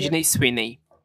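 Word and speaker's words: Sydney Sweeney: É assim que fala? Como é que é? – Pronuncia. Sidney Sweeney.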